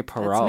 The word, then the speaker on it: Perrault: Please welcome Valerie Perrault. Is that how you say it? – Yep, Perrault. – Valerie Perrault.